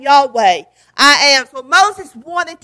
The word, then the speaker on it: Yahweh: The name Yahweh, Hebrew word Lord, and it's pronounced Yahweh, and it's built on the word I am.